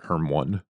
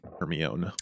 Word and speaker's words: Hermione: When I read that book, I pronounced it Hermione. – I think I pronounced Hermione for a long, long time. – Hermione. – Sorry, Hermione is not a name that exists in America.